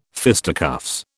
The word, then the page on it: fisticuffs: British English